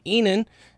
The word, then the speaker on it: Susan Enan: Tell me about Susan Enan a little bit. Am I saying that right? – I think so. Enan.